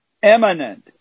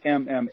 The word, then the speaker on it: immanent: Eminent. I concentrate on the middle syllable because it's not eminent, which is more like now or about to happen. – Yeah. Right. – This is a word that's immanent.